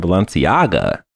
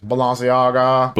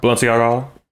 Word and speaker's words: Balenciaga: Prices for Fortnite's collaboration with fashion brand Balenciaga will make your eyes. – You're saying it wrong. It's Balenciaga. – Balenciaga.